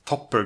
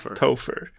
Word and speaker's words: Topher: PH om det ska vara F. Topher, Topher Grace. – Topper Grace, nej. – Topher. Nej, Topher.